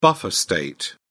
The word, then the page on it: buffer state: British English